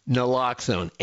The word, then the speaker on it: Naloxone: Naloxone, N as in Nancy, A-L-O-X-O-N-E.